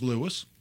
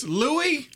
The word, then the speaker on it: Louis: It's like Louis Vuitton. – That's why you're a Louis. – Surf. – Louis. – That's why I'm a surf. So wait a minute. It's Louis? – Louis.